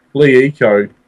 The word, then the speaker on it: LeEco: it's spelt LLECO. So it'll be Leeco, more or less, I guess.